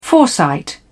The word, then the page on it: foresight: British English